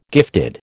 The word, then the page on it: gifted: American English